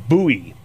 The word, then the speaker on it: Bui: And the second part of his name is Bowie, B U I. So P H U C B U I. – Right. So his name is Fook Bowie.